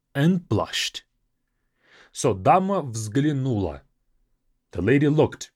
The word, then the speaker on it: взглянула: Взглянуть, even though it has four consonants in the beginning, is a difficult thing to pronounce, but try to say it after me. взглянула. Взглянула. So взгл is the most difficult part.